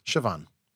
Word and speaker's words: Siobhan: I'm going to throw you a Gaelic pronunciation guide here, and I'm going to tell you that B is more like a V. – Hmm. – Siobhan.